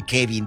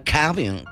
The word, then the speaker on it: Kevin: No dicen Kevin, dicen Calvin.